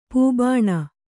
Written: ಪೂಬಾಣ

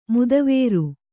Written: ಮದವಾರಿ

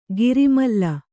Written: ಘೋರಾಮ್ಲಿ

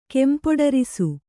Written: ಕೆಂಪೊಡರಿಸು

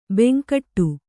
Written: ಬಾಂಗಡಿ

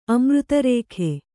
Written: ಅಮೃತರೇಖೆ

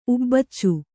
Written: ಉಬ್ಬಚ್ಚು

